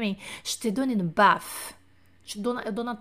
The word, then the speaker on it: baffe: Je te donne une bif. Não é bife, é bafe. Je te donne une bafe. B-A-F-F-E, se eu não estou enganada, tá?